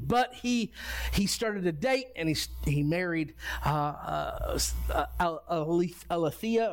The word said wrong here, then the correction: Athaliah: or Athaliah. That's how you pronounce her name.